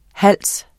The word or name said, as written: HALSENES